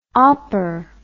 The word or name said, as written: opper